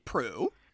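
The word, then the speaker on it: Proulx: This film that we're discussing today is based off of a short story by Annie Prowl. – Yeah, I couldn't wait to hear you try and pronounce Prue. This woman's last name. – Is based off of a short story by Annie Prue.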